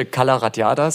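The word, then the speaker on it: Cala Ratjada: Es müsste Calaratiada sein. – So einfach spricht man das, Calaratiada? – Äh, ja, ja, okay.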